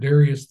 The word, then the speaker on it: Darius: And in the first year of Darius the Mede, I took my stand to protect him."